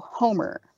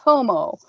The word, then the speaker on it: Homer: Also, Homer, not Homo, played by... – as he says when he grabs him by the mouth, Homer, H-O-M-E-R, mispronounced, and I wouldn't want to be you.